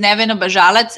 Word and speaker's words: Nevena Bazalac: So my full name is Nevena Bazalac.